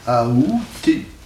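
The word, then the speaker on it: aoûter: La prononciation qui est considérée comme correcte, c'est la prononciation avec A. Aouter.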